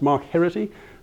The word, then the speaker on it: Mark Heraghty: So my name is, it's pronounced Mark Heraghty.